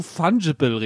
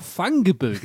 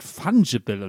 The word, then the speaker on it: Fungible: Der erste Hinweis ist, ich gebe dir den Punkt alleine, weil du Fungible richtig ausgesprochen hast, während ich vor zwei Wochen noch Fungible gesagt habe. Ich habe gerade hektisch nebenbei gegoogelt. Natürlich hatte ich wieder Unrecht. Natürlich heißt das Wort Fungible und nicht Fungible.